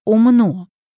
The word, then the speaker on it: умно: Но в слове «умно» или «умно» ударение на последний слог.